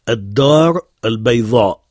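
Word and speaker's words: ad-dar al-bayva (الدار البيضاء): Et qui se prononce en arabe « ad-dar al-bayva ».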